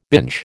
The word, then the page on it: binge: American English